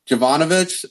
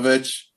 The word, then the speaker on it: Jovovich: With Mila Jovanovic, is how you say her last name? Mila Jovovich? Jov Jovovich, yeah, something like Jovich. that. – Jovovich.